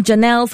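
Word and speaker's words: Jeynelle: You've been with me, Janelle, for the past hour, a new voice on Kiss 92.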